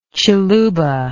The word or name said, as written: chiluba